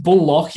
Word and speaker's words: Bullock: You mean Reggie Bullock? – Bullock, yes. – The American pronunciation.